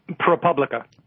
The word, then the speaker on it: ProPublica: Am I pronouncing that right, Anthony? – ProPublica. – Publica. Yeah. Okay. Mm-hmm. Okay. The anglicized version, I guess.